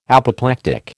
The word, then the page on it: Apoplectic: American English